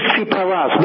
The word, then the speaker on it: Tsipras: Tsipras? – Alexis Tsipras.